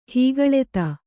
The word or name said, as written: ಹೀಗಳೆತ